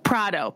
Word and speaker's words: Prado: Prado. – Pamela Prado. Like Prada, but Prado. Yeah.